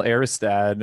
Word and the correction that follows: Aristide: or is that how you say it? I hope that's how you say it. – I think it's Aristide. – Aristide, okay.